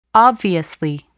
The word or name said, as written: obviously